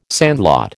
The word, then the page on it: sandlot: American English